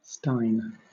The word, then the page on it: stein: British English